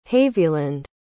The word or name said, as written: haviland